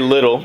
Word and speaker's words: Liddell: But his parents were the Reverend James and Mary Little. And it's spelled like Liddell, L-I-D-D-E-L-L. But I've always heard it pronounced Little.